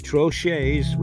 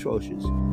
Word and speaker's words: troches: I believe it's troches or troche. I'm going to go with troches.